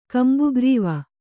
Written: ಕಂಬುಗ್ರೀವ

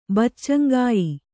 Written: ಬಚ್ಚಂಗಾಯಿ

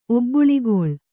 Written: ಒಬ್ಬುಳಿಗೊಳ್

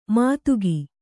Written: ಮಾದಗ

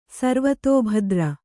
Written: ಸರ್ವತೋಭದ್ರ

